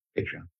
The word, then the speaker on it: Patreon: And then later in the podcast, they gave up and said, it's actually pronounced Patreon.